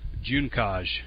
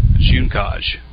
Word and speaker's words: Juncaj: Juncaj. I asked him, and he sent me a voice recording of himself saying it. Juncaj. It's like the month of June.